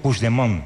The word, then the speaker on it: Puigdemont: Me has preguntado antes, ¿cómo se dice este nombre? – Sí, voy a intentar. ¿Eh? – No, Puigdemont. – Puigdemont. – Vale. – Puigdemont. – Eso es. – Sí, lo dices como francés, así.